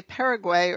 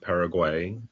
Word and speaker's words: Paraguay: You know, I grew up pronouncing it Paraguay, but learned from other Spanish speakers in college and then in grad school, maybe a more authentic pronunciation, Paraguay. So either one.